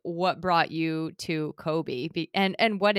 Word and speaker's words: Kolbe: So Kobe, and if you're listening, it's spelled K-O-L-B-E. Kobe is a conative index.